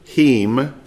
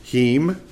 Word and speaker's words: him: We say him, but if we were pronouncing it like they did, heem, okay? The I is kind of pronounced like an E, heem.